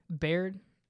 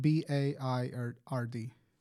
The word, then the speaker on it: Baird: What how do you pronounce that? – Baird. – How do you spell it? – B A I R D.